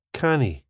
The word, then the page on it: canny: スコットランド英語